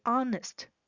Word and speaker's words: honest: To be honest，这是个片语，老实说。请注意，honest，h-o-n-e-s-t 的 h 是不发音的。 Honest，honest。